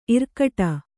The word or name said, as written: ಇರ್ಕಡೆ